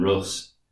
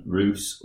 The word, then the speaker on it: Rus: No, yeah, Ben very kindly messaged us from Middle Earth and told me that it's not Kiev and Rus, it's Kiev and Rus, like goose or juice.